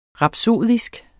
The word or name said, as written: rapsodisk